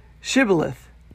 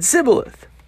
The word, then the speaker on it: Shibboleth: They said, Then say Shibboleth. And he said Sibboleth. In other words, he couldn't pronounce the word right, for he couldn't could not pronounce it right.